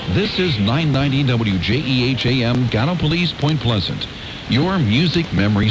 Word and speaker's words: Gallipolis: but I can tell you that I learned early on how to pronounce the U.S. Naval Academy city of Annapolis. And if you know how Gallipolis is spelled, you'll see where my confusion came in.